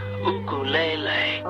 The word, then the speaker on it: ukulele: The name is actually pronounced ukulele, which in Hawaiian means the gift that came here.